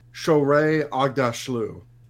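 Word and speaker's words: Shohreh Aghdashloo: Shorey Agdashlu. That's how you pronounce it.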